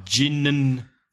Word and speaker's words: gin and tonic: But it's not, you don't really hear the and. Let's look at some pronunciation here. It's more of a So it's ginnin.